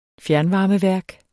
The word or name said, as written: fjernvarmeværk